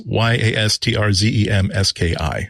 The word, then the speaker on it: Yastrzemski: Yastrzemski.